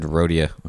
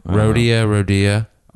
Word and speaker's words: Rhodia: And Rhodia. I don't know if that's how you say it. – I always just said Rhodia. – Rhodia. R-H-O-D-I-A.